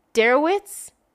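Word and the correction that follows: Deresiewicz: No, Derezowitz. That's how it's pronounced.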